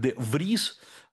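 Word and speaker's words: de Vries: Кстати говоря, имя де Фриза, иногда произносится как де Вриз,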